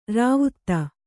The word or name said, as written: ರಾವ್ತ